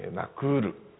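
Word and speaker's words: Nakuru: それでケニアの言葉は全部全部です、例外なくおしまいから 2 番目のシラブルがアクセントがあるんです。ですからとても発音が分かりやすい、ナイロビ、ナクールという感じであります。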